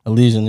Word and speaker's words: Elysian: Elysian, yeah.